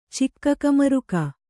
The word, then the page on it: ಚಿಕ್ಕಕಮರುಕ: Kannada